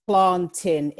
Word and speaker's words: plantain: Some people call it plantains, but just as a little planting. That's how you say it then. – Plantain. It's planting. It's a bit like saying Great Britain. It's Great Britain. Yeah, it just doesn't make any sense. So it's planting.